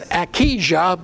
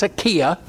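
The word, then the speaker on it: Ahijah: You're probably reading it as Akijah, but the spelling is not how we would pronounce it. It's Akiah.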